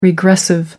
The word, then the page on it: regressive: American English